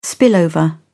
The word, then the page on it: spillover: British English